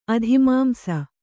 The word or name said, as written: ಅಧಿಮಾಂಸ